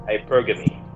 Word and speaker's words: hypergamy: Hypergamy.